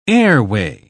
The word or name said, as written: airway